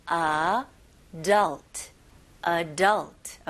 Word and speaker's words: adult: 여기서는 adult, adult. Okay, 강세가 어디 들어가는지 혹시 들리시나요? 네, 두 번째 음절에 들어갑니다. 그래서 adult. Adult.